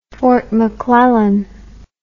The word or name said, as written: fort mcclellan